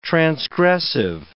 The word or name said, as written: transgressive